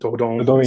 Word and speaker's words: Dordogne: Um, uh, Dordong, or I, I, hope that's how you say it. – Dordogne, sounds French. Dordogne.